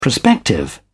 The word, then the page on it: prospective: British English